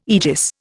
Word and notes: aegis: Indian

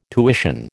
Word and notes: tuition: American English

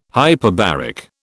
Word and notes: hyperbaric: British English

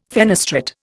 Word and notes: fenestrate: Indian

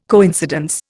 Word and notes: coincidence: Indian

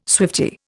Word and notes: swiftie: Indian

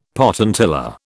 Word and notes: potentilla: British English

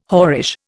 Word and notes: whorish: Indian

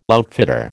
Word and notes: outfitter: American English

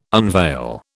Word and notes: unveil: British English

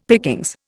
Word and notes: pickings: Indian

